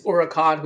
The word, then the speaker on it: Huracán: It starts with an H, but the H is silent in Spanish. So you could say it's Huracan, which would be Spanish for hurricane.